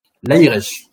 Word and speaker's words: Laïreche: Laïresh. – C'est ça. Donc le le le, tréma n'est pas innocent.